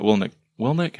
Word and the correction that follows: Willink: how do you say his last name? – Willink talks about how the SEALs divisions of labor, essentially you can only effectively lead like five people, mm-hmm.